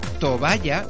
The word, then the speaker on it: tobaya: Pero es que según la RAE, está bien pronunciado. De hecho, tobaya es directamente el sinónimo de toalla. Así que tanto toalla como tobaya se pueden decir tranquilamente.